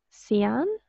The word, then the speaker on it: Cyan: I literally just listened to a podcast interview with, I never know how to say this, Cyan. – Yeah, like as in the color, like yes, the color cyan.